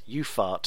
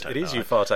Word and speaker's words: Ufarte: And he said, but best was getting the Lopez Ufart sticker in España 82. I'm assuming that's how you pronounce it. It could be Ufarte, but it is Ufarte. I'm reliably informed.